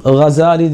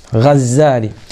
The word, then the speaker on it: Gazzali: Çünkü diyor Gazzali'nin, hani burada yeri gelmişken söyleyeyim, Gazzali diye de okunur, Gazali diye de okunur. İhya ulumiddin sahibi. Genelde Gazali diye okunuyor ama daha doğru okuyuş abiler Gazzali'dir. Gazzali.